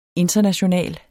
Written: internationale

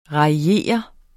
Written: raillere

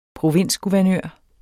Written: provinsguvernør

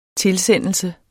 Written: tilsendelse

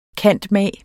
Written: cand.mag.

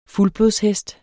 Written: FULDBLODSHEST

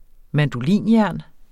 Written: mandolinjern

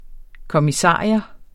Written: kommissarius

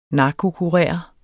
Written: narkokurer

